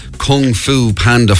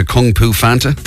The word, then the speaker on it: Kung Fu Panda: PJ mentioned she was in Kung Fu Panda 4. I slightly mispronounced it into Kung Fu Fanta.